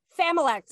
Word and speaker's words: familect: How do you say Familect? – Familect. So it's F-A-M-I-L-E-C-T. – L-E-C-T. Familect.